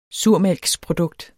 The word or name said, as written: surmælksprodukt